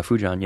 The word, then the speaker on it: Fujian: Now, these folks had in the main come from Fujian. Am I pronouncing this right, this province in southern China? – Yeah, Fujian, yeah.